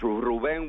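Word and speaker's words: Rubén: Ruben is not Ruben. It's Ruben with an accent over the E.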